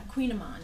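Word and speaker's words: kouign-amann: Queen Amon is how you say it, plural, Queen Amans. And it's spelled like you would never think Queen Amans. It's spelled K-O-U-I-G-N.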